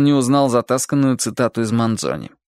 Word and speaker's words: Манзони: А поэт Манзони, лучше даже Манзони, с ударением на последнем слоге,